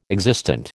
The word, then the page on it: existent: American English